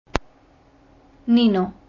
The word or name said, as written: Nino